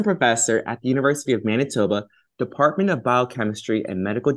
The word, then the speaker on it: Manitoba: We have Dr. Paul Marcogliese, who is currently an assistant professor at the University of, I should have asked you how to pronounce it, Manitoba? – Yes, perfect.